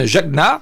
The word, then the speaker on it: Jagna: Au 19e siècle, dans un village polonais en ébullition, la jeune Jagna, parce que ça se prononce comme ça, promise à un riche propriétaire terrien, se révolte.